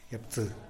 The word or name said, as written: yapsï